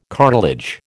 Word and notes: cartilage: American English

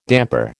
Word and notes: damper: American English

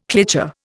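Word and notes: cliché: Indian

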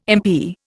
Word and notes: impi: Indian

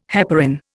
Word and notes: heparin: Indian

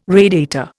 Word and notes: radiator: Indian

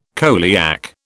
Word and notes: coeliac: British English

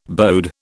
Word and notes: bode: British English

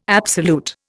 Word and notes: absolute: Indian

